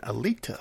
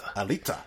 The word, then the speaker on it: Alita: Now, Alita Battle Angel, I like your pronunciation, Alita. – Alita.